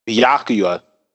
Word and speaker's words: Byakuya: What is it? Bayuka? How do you say his name? – Byakuya.